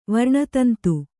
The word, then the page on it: ವರ್ಣತಂತು: varṇa tantu